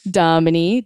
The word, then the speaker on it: Dominey: Domini, D-O-M-I-N-E-Y.